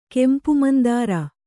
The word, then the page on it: ಕೆಂಪುಮಂದಾರ: kempu mandāra